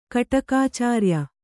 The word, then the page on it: ಕಟಕಾಚಾರಿಯ: kaṭakācāriya